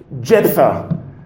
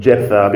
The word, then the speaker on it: Jephthah: You can pronounce it. Actually, in the original, it's neither of those. It's Yiftah, which means to open. But I'll probably call him Jephthah because that's the way it sits in my mind, and I don't want to confuse my mind too easily.